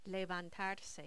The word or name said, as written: levantarse